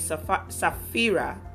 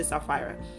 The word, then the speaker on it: Sapphira: It says, But a certain man named Ananias with Sapphira, that's how I would pronounce it. Some people say Sapphira, his wife, sold the possession and kept back parts of the price.